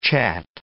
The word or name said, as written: chat